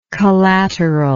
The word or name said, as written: collateralness